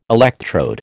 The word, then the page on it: electrode: American English